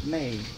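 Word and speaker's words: may (μή): If my kingdom were of this world, then would my servants fight that I should not," Greek word "may," spelled M-E, pronounced "may," Strong's 3361, a qualified negation, that "I should not be delivered to the Jews."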